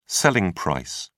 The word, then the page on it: selling price: British English